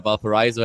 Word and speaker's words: Valparaiso: I mean, my girlfriend is from Fort Wayne, so we saw all the she went to Valparaiso, so we've been at Valparaiso at the at the campus, then drove to Say it like somebody from Indiana. Say Valparaiso. – Valparaiso. – Okay, stick with Valparaiso. I like that better.